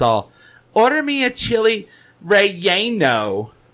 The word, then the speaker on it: relleno: But I've looked it up, and apparently the Spanish-speaking world says chili relleno. Relleno.